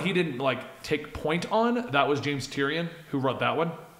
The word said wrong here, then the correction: Tynion: James Tynion? – Tynion, that's it. I'm sorry, I knew I was saying his name wrong.